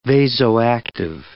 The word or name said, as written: vasoactive